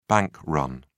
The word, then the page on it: bank run: British English